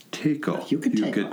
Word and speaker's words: Yucateco: Yucateco. I think that's how it's pronounced. – I think that is right.